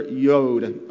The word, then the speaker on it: Yod: Yod, and it's pronounced Y-O-O-D, Yod, but I may say Yod quite often.